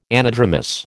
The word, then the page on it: anadromous: American English